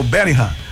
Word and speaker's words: Bellingham: Mas se escreve Bellingham, mas é o Bellingham.